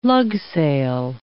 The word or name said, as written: lugsail